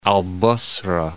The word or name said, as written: Al-Basrah